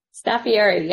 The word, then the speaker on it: Staffieri: Did I say that right, Catherine? – Staffieri, yep.